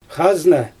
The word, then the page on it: xazna: Xyzyl